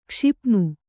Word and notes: ಕ್ಷಪಣ: kṣapaṇa

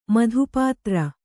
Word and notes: ಮಧುಪಾತ್ರ: madhu pātra

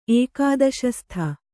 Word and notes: ಏಕಾದಶ ಶತ: ēkādaśa śata

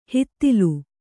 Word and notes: ಹಿತ್ತಿಲು: hittilu

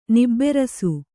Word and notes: ನಿಬಿರೀಸ: nibirīsa

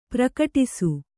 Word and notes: ಪ್ರಕಟಿಸು: prakaṭisu

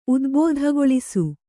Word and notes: ಉದ್ಬೋಧಗೊಳಿಸು: udbōdhagoḷisu

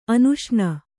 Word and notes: ಅನುಷ್ಣ: anuṣṇa

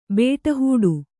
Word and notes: ಬಿಟ್ಟಿಹಿಡಿ: bi'ṭṭi hiḍi

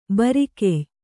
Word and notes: ಭರಿಕೆಯ್: bhari key

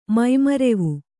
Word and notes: ಮೈಮರೆವು: mai marevu

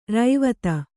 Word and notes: ರಾವ್ತ: rāvta

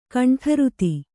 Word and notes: ಘಂಟಾರುತಿ: Kannada

